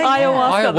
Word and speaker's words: ayahuasca: Ayahuasca. Yeah. – Ayahuasca.